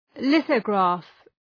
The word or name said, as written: lithograph